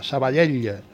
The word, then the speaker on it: Sabadell: Jacobo Heredia se queda, pero además le vamos a hablar de la victoria sobre el Sabadell, que es una L final y hay que pronunciarla, Sabadell. No como los que leen las quinielas que dicen Mallorca 2, Sabadell 0.